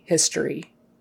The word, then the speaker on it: history: History. History. Not history. It's more of that history. And the accent is on the front.